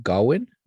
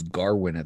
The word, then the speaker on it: Gawain: Dev Patel, you know, which he plays Gawain. I thought it was Garwin at the beginning, but it's Gawain.